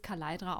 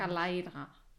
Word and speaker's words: Kaleidra: Ja, dann wird es Kaleidra ausgesprochen. Sind wir uns da einig? Kaleidra.